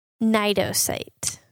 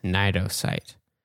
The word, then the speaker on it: Cnidocyte: It is the one that starts with the silent C. – Yeah. Cnidocyte. – Cnidocyte.